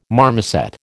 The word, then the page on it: marmoset: American English